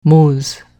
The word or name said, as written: mooz